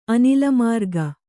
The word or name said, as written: ಅನಿಲಮಾರ್ಗ